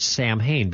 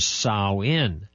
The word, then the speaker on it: Samhain: Now, I used to always call it or pronounce it Samhain, because that's what it looks like, Samhain. But according to Mosteller, the actual pronunciation is Samhain.